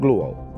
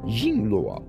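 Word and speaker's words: Jing Luo (鲸落): Ying jingluo, Jing Luo, Jing Luo, J I N G L U O, which is Whale Fall.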